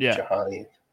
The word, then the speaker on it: Jhony: It's spelled J-H-O-N-Y. So I call him Johnny.